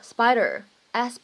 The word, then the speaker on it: Spyder: 那么什么叫做 spider？蜘蛛吗？其实呢，谈到车，如果你听到别人说 spider，s p i d e r，或者 spider，s p y d e r，发音呢是一样的。